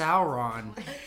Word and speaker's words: Samhain: It's pronounced Sauron. – It's weird. It's spelt not Samhain. – It's how you pronounce it, though.